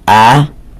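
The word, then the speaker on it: A-Do-Noi (אדני): rarely find it written the way on the top of the page where it's with the Aleph, Dalet, Nun, Yud, although that is pronounced A-Do-Noi. Unless you're a Sephardi, you'd be ending it off Nai.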